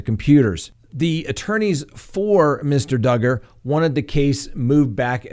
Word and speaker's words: Duggar: Next on the docket, Josh Duggar, rhymes with hugger.